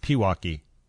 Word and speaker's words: Pewaukee: It's not Milwaukee. It's Pewaukee. – Thank you. – You're welcome. – That's how you say it? – Yeah.